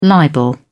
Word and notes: libel: British English

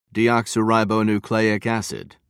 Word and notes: deoxyribonucleic acid: British English

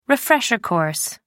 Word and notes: refresher course: British English

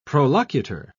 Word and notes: prolocutor: British English

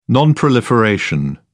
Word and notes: non-proliferation: British English

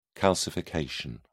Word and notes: calcification: British English

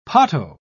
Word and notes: potto: British English

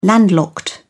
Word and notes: landlocked: British English

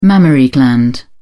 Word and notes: mammary gland: British English

